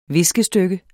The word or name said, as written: viskestykke